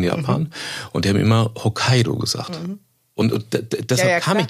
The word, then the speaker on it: Hokkaido: Eben nicht Hokkaido heißt, so wie wir es halt bei uns aussprechen würden, sondern also es kann man ja letzten Endes nennen, wie man uns sagen, wie man will, aber richtig, wie du es gesagt hast, Hokkaido.